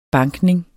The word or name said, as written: bankning